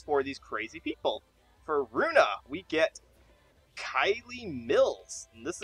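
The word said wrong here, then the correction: Kayli: Sorry, it's pronounced K-A-Y-L-I. I knew I was going to get that wrong. – Hey-o. – You know like Haley Mills?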